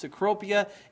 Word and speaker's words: Cecropia: Cecropia is spelled C-E-C-R-O-P-I-A, but the first C is a S sound, all right?